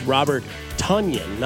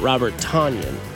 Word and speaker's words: Robert Tonyan: Sorry to be a thanks coach, but it's Robert Tonyan, not Robert Tonyan.